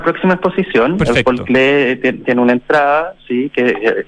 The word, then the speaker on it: Klee: Aprendí claro. A pronunciar Klee gracias a usted. Yo antes siempre decía Paul Klee.